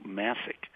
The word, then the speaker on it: Massek: So that's the story, and it stars this unbelievable singer and actor named Sue Masick. It's spelled M-A-S-S-E-K. It's a Bohemian name, not like New York Bohemians, Bohemia, from Europe.